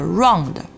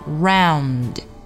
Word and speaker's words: round: 如果你舌尖没有顶上去的话，没有把这个前鼻音的动作发到位，做到位的话，那么很容易咱们就会发音往后靠，然后发出带有中文后鼻音的 round 这么一个音啊。所以说要注意前鼻音要发好 round。